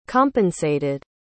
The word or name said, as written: compensated